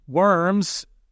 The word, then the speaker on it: Worms: Worms, pronounced Worms in the German language, was a city in Germany where Luther was put on trial for his teachings.